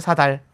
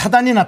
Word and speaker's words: 사달: 근데 사단이 났다고 얘기하는 사람도 있습니다. – 원래 옛날에는 사단 났다고 예. 했는데, 예. 근데 즉 KBS에서 이렇게 발음말 쓰기 운동을 펼친 이후로 사달 났다.